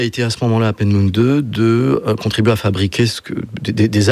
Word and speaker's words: Peenemünde: qui s'appelait Peenemünde, je crois que c'est comme ça qu'on prononce. – Tout à fait ça.